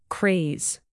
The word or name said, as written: craze